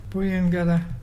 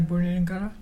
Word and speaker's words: buyungarra: Booyangurra is turtle, and it's spelt B-U-Y-U-N-G-A-R-R-A. Can you say Booyangurra?